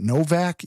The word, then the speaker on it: Nowak: Henry, and I guess they pronounce it Novak, even though it's N-O-W-A-K. I would say Nowak, but it sounds like everybody over there is saying Novak.